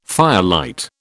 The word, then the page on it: firelight: British English